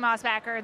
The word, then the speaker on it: Mosbacher: If you say Mossbacker, I say Mossbacker. This is the American bastardization of my name.